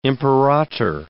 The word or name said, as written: imperator